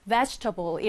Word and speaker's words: vegetable: 자, 여러분 여기 보시면 table이라고 하는 단어가 숨어 있지만 여기서 table이라고 발음하지 않아요. vegetable 이렇게 발음해 주시면 됩니다.